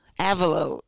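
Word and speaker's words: Avelo: And now we know that, how do I say it, Avalo or Avello? Avalo.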